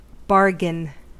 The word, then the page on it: bargain: American English